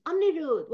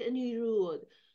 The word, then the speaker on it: Anirudh: It's Anirudh. Why is he calling it Anirudh? That's because in English it is pronounced like that.